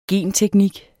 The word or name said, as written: genteknik